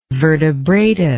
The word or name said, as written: vertebrata